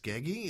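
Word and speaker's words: Skeggi: So I finally found some information on some Norse guys that settled down over in Lustria in the town of, I guess it's pronounced Skeggy. It's S-K-E-G-G-I.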